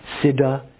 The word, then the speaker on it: Siddha: Siddha, S-I-D-D-H-A, that's a pretty good rendition of the Sanskrit.